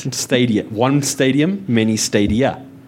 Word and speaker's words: Stadia: So it is pronounced Stadia.